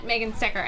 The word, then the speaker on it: Megan Stecher: It's all at Megan Stecher, and that is spelled MEGANSTECHER.